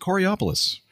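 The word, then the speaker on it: Coraopolis: We clarified that, and they said, you pronounce it Coriopolis.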